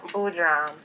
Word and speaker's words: Boodram: Shan Boodram.